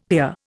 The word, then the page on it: pier: Indian